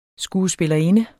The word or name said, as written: skuespillerinde